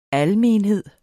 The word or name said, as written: almenhed